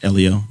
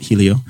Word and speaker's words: Helio: We've all agreed on common pronunciation of Elio, not Helio.